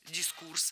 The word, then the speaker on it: дискурс: Запомнить ударение в этом слове легко с помощью другого простого слова «курс». Курс – дискурс.